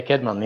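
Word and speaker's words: Cædmon: Is it Caedmon? – Yeah, Caedmon. Yeah.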